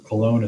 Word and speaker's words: Colone: Colone is spelled C-O-L-O-N-E.